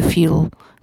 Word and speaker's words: futile: You might also note some slight difference from the general American sound in the word futile, which she pronounces almost as RP, as opposed to the fuel that you would expect to hear in American.